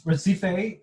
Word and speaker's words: Recife: How do you pronounce it? – It's Recife, Chife.